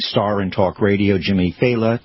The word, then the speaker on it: Failla: His name is pronounced Failla. Failla. It's spelled F-A-I-L-L-A, so people go Failla, Failla.